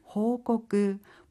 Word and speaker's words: 報告: 보고할 때 고는요, 꼬끄라고 읽습니다. 그래서 보고는 호꼬끄, 보고하다는 호꼬끄스르 3그룹 동사가 되겠습니다.